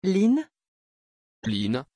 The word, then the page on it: Lynn: French